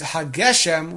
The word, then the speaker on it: Hageshem: If Mashiv HaRuach is really an integral part of the text of the bracha, therefore one should pronounce it Geshem with a segel, which is used to show continuation, and Hageshem, which is used to show, Hageshem shows the end of an idea.